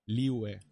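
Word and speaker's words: Liyue: Liwe se dice. Se Liwe. – Liwe.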